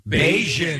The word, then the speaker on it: Bajan: I think it's actually Bajan. – Bajan. Yeah. – That's how you say it.